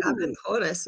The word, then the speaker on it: Aventuras: Yes, Aventuras. Did I pronounce it right? – Ooh. Yes, Aventuras is how they say it.